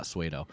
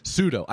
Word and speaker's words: pseudo: It's not suedo. Pseudo.